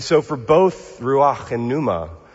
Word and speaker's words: pneuma: Pneuma. Yeah, pneuma. It's got often a silent P at the beginning of it.